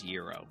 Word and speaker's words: gyro: It's Greek. I think the pronunciation is gyro.